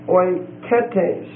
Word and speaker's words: oiketes: Next word is oiketes. Oiketes. O-I-K-E-T-E-S. And the last E is a long E.